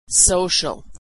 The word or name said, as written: social